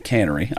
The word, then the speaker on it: Canary: That's how I've always heard their name said. It's spelled Canary, but Cannery.